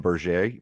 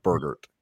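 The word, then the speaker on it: Bergert: I was trying to go Ryan Berger. It of course is not. It's like it essentially rhymes with like Go-Gurt, so it's like Burgert.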